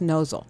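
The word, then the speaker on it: Mnozil: It's M-N-O-Z-I-L, but it's pronounced Nozzle. Right. Silent M.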